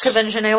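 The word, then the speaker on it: Quvenzhané: Kevin Janae Wallace.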